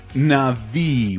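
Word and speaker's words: Na'vi: It'll scan your body and face and turn you into a Navi, which is the proper pronunciation of this thing no one remembers!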